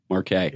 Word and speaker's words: Markay: Did I get that wrong once already? – Yeah. – Markay. – Yeah. Markay. – Emphasis on the K. – Kind of like parquet.